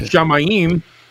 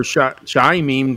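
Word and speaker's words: Shamayim: Shamaim, or Shaimim, depending on your pronunciation.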